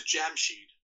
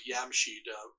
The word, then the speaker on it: Jamshid: His name is Jamshid, or sometimes Yamshid, if the J is pronounced, is silent.